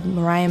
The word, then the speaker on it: Maria: I think the book is called Mariah Mitchell. It's spelled Maria. It's spelled like my name, but her name is pronounced Mariah, M-A-R-I-A, Mitchell.